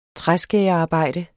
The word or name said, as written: træskærerarbejde